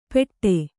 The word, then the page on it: ಪೆಟ್ಟೆ: Kannada